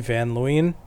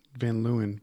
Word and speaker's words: Van Leeuwen: I think it's supposed to be Leeuwen, Van Leeuwen, but it's spelt Leeuwen, but who knows? – Leeuwen, let's go with that.